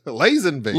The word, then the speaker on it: Lazenby: It's Lansenby. – Lazenby. – Lazenby. – Yes. – My God, I feel like an idiot. Man. I've been mispronouncing his name for so long.